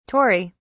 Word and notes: Tory: British English

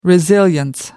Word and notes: resilience: American English

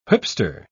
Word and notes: whipster: British English

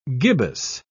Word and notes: gibbous: British English

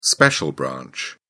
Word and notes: special branch: British English

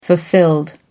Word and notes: fulfilled: American English

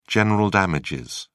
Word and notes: general damages: British English

